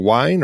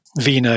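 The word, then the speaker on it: Wiene: is it Robert Wien or Wine or? – Wiener. Vina is how you pronounce it, yeah.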